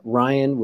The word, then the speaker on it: Ryan: It's Ryan, which is obviously R-Y-A-N. And then the last name is Shaloya, S-C-I-A-L-O-I-A.